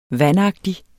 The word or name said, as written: vandagtig